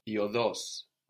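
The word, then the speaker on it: οδός: iodos, with the accent on the end, iodos.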